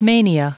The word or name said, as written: mania